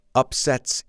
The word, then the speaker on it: upset: So please repeat, the underdog upsets its opponent, and it's called an upset. Do you hear the difference? The noun is pronounced upset, and the verb is upset.